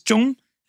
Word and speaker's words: Trung: In Vietnam, the way you pronounce it is Trung. And I didn't realize that until I moved to Vietnam when I was 23. So I lived 23 years with the white pronunciation of my own name.